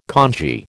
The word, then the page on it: kanji: American English